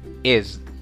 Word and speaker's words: is: Is. Nótese y pon mucha atención en la, pronunciación. Eh, no es is como una serpiente. En este caso, vamos a la S suena como una Z en inglés.